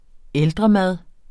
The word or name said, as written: ældremad